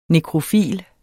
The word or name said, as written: nekrofil